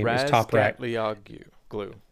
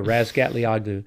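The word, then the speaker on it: Razgatlıoğlu: Toprak Razgatlioglu, I can never say his name. – Razgatlioglu. – There you go, Razgatlioglu.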